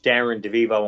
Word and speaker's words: Darren DeVivo: And the name is spelled DarrenDevivo. Go to my radio Facebook page, Darren DeVivo on WFUV Radio.